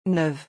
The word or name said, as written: neuve